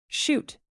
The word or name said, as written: chute